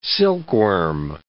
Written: silkworm

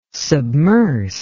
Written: submerse

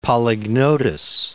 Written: Polygnotus

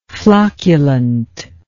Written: flocculently

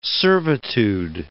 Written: servitude

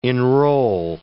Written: enroll

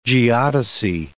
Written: geodesy

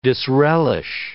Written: disrelish

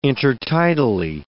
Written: intertidally

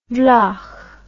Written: wlach

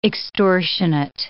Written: extortionate

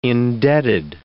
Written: indebted

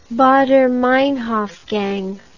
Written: baader meinhof gang

